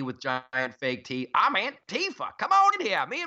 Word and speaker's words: Antifa: I don't want to be on a beach beating up some anti-fa. They need a better name. Can we give them a nickname? We should have a contest. – It's actually pronounced Antifa.